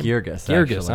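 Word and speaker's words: Guirgis: Now, this play was authored by Stephen Adley Geirgus. Is that how you say his name? – Geirgus, I'm sorry.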